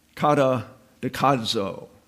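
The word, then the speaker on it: kata dekadzo (καταδικάζω): There's that Z again that requires a D in pronunciation. You can't say dekadzo. You have to put a D in front of it if you want to pronounce it properly.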